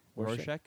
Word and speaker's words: Rorschach: And then I wanted to be, let's see if I pronounce this right, Warshek. – Rorschach? – From Watchmen.